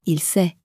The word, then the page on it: il sait: French